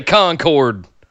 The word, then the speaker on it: Concord: We don't say it like you, Northern California. We say Concord.